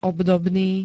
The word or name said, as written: obdobný